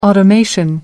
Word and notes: automation: British English

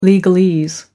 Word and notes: legalese: American English

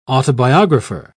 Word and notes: autobiographer: British English

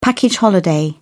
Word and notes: package holiday: British English

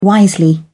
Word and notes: wisely: British English

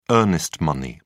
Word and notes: earnest money: British English

